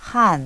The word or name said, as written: han